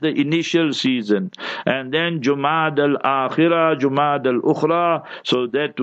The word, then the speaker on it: Jumada: See how many ulama pronounce it wrong. Jumada with a dhamma, pesh on the jim. Jumada.